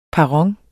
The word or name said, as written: PERRONERNES